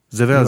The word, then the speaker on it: Zvezda: Zvezda.